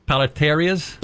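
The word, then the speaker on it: paleterías: Now, they say that there are paleterias. – Paleterias. – Okay, paleterias. – See, when there's... – Tish. – There's an accent on the I.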